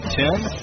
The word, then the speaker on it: Then: Last name is T-H-E-N, pronounced Ten.